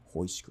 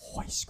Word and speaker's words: Royce: Hoist Gracie. – Yes. – Hoist Gracie. It's spelled Royce, but it's Hoist Gracie. – Yeah, they Portuguese. They got the pronunciation on point.